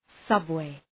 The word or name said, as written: subway